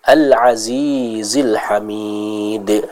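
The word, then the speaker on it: al-azizil hamid (الْعَزِيزِ الْحَمِيدِ): Di sana kita jelaskan ya pengucapan huruf ain dan huruf ha ya. Al-azizil hamid ya.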